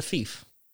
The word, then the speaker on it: fief: Also, I was corrected recently that it's pronounced a fief. – I saw that, yes. – Yeah. – Yeah, I called it a fife a few times.